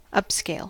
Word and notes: upscale: American English

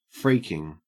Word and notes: freaking: Australian English

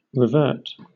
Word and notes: revert: British English

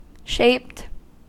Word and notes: shaped: American English

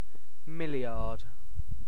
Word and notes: milliard: British English

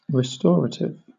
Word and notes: restorative: British English

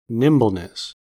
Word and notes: nimbleness: American English

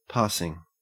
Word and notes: passing: Australian English

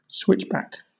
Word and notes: switchback: British English

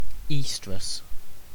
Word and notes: oestrus: British English

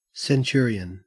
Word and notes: centurion: Australian English

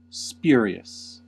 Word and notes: spurious: American English

